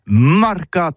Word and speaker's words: marcat: Eh bien, d'ici à tout, mais le mercat, de l'Uni ou le mercat des rebelles, mais en gascogne toulousain, c'est prononcé sur les marcat, avec un A marcat. En gascogne toulousaine, bah, du côté par exemple de l'île des Baches, c'est l'île Jourdain, pour parler du marché, vous prononcez les marcat.